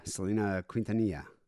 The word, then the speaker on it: Selena Quintanilla: We have Selena Quintanilla. I said that right, didn't I? – Yeah, Selena Quintanilla.